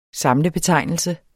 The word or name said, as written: SAMLET